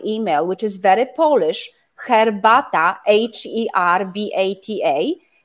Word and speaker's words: herbata: So herb is herbata. Is silent in English, but in Polish, you hear PH, herbata.